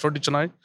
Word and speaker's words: roti canai: And then I thought, why not roti canai? Because I don't really see someone make fresh roti canai in London. – Yeah. – So we got the idea to making roti canai and make it affordable in London.